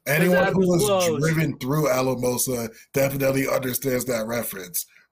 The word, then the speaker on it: Alamosa: was pronounced Asamola, because my father refuses to pronounce it Alamosa.